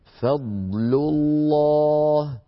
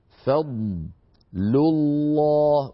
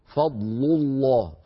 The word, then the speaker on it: فضل: هكذا يجب أن تكون هكذا، فضل الله، فضل الله، ولا نقول فضل الله، فضل الله، لا نفخم لا ما فضله.